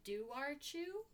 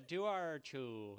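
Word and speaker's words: Dobharchú: So Ireland has this otter/hound hybrid called the Duarchu. Duarchu? I am so sorry. – Formal apology. Ireland. – Ireland. There's a B in there, but the B is not in the pronunciation.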